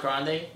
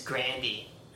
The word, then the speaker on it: Grande: You know, it's not even pronounced Grande. – What is it? – It's Grandy. – Is it? – Yeah, that's what she says.